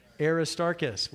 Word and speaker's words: Aristarchus: Aristarchus.